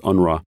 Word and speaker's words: UNRWA: Well, the agency is called the United Nations Relief and Works Agency, which we pronounce UNRWA.